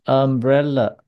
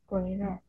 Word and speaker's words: umbrella: 所以我们说 banana 香蕉，或是 umbrella 就是念呃，对，我们念一下 umbrella， Umbrella。 – yeah umbrella umbrella 是呃的音，对，有人说啊不能念啊哦，念啊的话这样发音就不是很准确了。